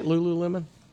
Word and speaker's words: Lululemon: And Lululemon's quarterly results. Did I say that right, Lululemon? – Lululemon, yeah.